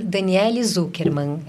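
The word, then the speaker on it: Daniele Zukerman: Daniele Zuckerman. É D-A-N-I-E-L-E-Z-U-K-E-R-M-A-N.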